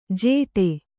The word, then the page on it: ಜೇಟೆ: jēṭe